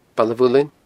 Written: Baile a' Mhuilinn

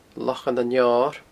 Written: Lochan an Fheoir

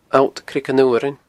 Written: alltcreaganfhuarain

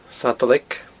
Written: Sadalaig

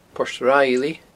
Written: Port Riley

